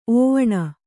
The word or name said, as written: ಓವಣ